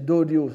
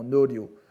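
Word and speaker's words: Noryo (納涼): Alors, c'était prononcé Doryo, sans doute, à l'époque, un peu comme Dairi, n'est-ce pas ? Et maintenant, on le lit généralement Noryo, qui signifie l'action d'aller chercher la fraîcheur dans une villégiature ou tout simplement de prendre le frais, Suzumi.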